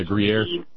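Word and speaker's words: Gruyère: Yeah, Gruyere.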